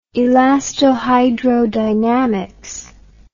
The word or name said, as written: elastohydrodynamic